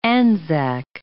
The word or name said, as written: Anzac